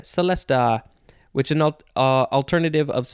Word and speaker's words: celesta: Next is celesta, or you could say celesta with a ch sound, or you could say celeste, spelled with an e at the end instead of an a. Celeste, celesta, celesta, celeste, all of those.